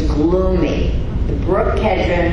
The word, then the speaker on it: Kidron: Am I saying that right? Kedron? Kedron? K-I-D-R-O-N. Kedron. Okay.